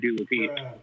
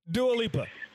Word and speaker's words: Dua Lipa: I can't hear the correct pronunciation of Dua Lipa anymore. – That's that's fuck. Good job, Wendy Williams. Anywho, Dua Lipa. That's her name, and I'm saying it right, and I didn't even know it.